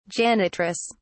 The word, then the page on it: janitress: British English